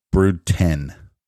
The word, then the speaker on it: Brood X: Brood ten pronounced. I'm sorry, I read brood X, but that's Roman numerals. It says pronounced ten.